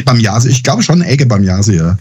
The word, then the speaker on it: Ege Bamyasi: Ja, ich weiß nicht, ob ich es richtig ausspreche. Ege Bamyasi. – Ja, genau, was heißt das denn? – Das ist, die Band heißt der Can.